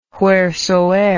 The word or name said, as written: wheresoeer